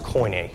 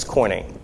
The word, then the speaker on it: koine: and it's spelled C O I N E, which is pronounced koine, see like just a coin, and then the letter A, it's koine.